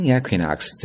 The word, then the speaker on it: equinox: 春风我们叫做 spring equinox，注意啊，第二个词读作 equinox，equinox 它是一个名词，昼夜平分点的意思。那么从 spring equinox 再到夏至，我们叫 summer solstice，可以看到呢是在左上角的位置，上面写的文字呢是 days longer than nights。